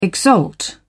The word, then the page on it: exalt: British English